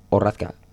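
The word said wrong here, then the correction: Rakta: Racta, sí. – Racta. Joder, siempre lo digo mal.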